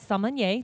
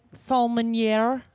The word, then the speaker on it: sommelier: And just to name a few, he's a certified sommelier. Did I say it right, Brad? – Nope. – Did I? Sommelier. – There's no N in there. – Sommelier. – No, get rid of that N.